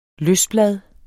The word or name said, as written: løsblad